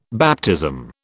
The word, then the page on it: baptism: British English